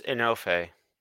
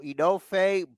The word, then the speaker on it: Enofe: Is it pronounced enough like you're enough? – Idris Enofe. – Oh, it's almost like enough. If you read it, it's Enofe. So Enofe, Blade, and OJ.